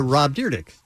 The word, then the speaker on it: Rob Dyrdek: Rob Dyrdek.